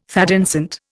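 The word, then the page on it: thé dansant: Indian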